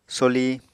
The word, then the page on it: soli: Apatani